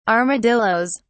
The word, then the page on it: armadillos: British English